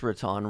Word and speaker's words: Riton: This is, I don't know if it's Riton. I think it's Riton. Riton, R-I-T-O-N, and Nightcrawlers.